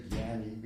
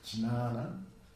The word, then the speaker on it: jnana: In Sanskrit, there's two words, jnana, jnana, really, if you pronounce it correctly, we say jnani because we're speaking like Bengalis or Hindis, but it's jnana.